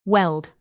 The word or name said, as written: weld